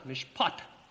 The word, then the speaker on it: mishpat: The Hebrew word translated justice is pronounced mishpat, M-I-S-H-P-A-T, and the T is a double stop, and you say ta, mishpat.